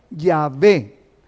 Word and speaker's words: Yahvé: Entonces, los hebraístas, o sea, la gente que estudiaba hebreo, han llegado al acuerdo general de que la pronunciación original debe de haber sido Yahvé.